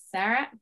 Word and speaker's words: Sarah: Welcome to the show, Zara. How are you? No, it's Seth. – Sarah. It's okay.